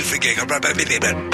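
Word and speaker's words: Fagerbakke: Is that how you say his name? – Yes, Bill Fagerback.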